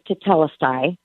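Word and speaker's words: tetelestai (τετέλεσται): Although it is a very profound meaning behind it, how you pronounce it is tetelestai.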